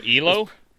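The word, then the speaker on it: Ehlo: No, I'd probably say Elios. Am I saying his name right? Whoever Jordan hit the shot over. – Oh, Elo? – Elo, yeah, sorry.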